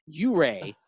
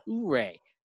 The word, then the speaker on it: Ouray: Secondly, I want to address the pronunciation of my username is hip, hip, ooray, not ooray. Don't worry, though, Zach. You're not the only one who would have pronounced it that way. I got the name based off my hometown of Ouray, Colorado, which is a small mountain town located in the heart of the San Juan Mountains in the southwest corner of the state.